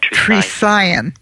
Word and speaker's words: Trisagion: A Trisagion. A Trisagion.